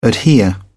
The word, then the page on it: adhere: British English